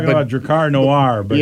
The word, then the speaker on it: Drakkar: It's D-R-A-K-K-A-R, and then noir. So the R is silent at the end of Drakkar.